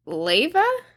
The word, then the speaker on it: Leyva: How do you say that? Leyva? – Yeah.